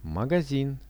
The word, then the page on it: magazin: Russian